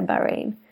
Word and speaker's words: Bahrain: Det är Bahrain. Eller ja, man får uttala det som man vill. Men vi har googlat oss till att man ska säga Bahrain. – Precis. Det stavas alltså Bahrain.